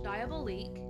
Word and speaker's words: Diabolique: And on the 4th, I watched, and it's a French film, so God knows if I'm saying this correctly, but I think it's Diablo League. Can you Google how to say that word? Let's find out, shall we? Because God knows I don't know how to say it. Diablo. All right, let's try that again. On the 4th, I watched Diabolique.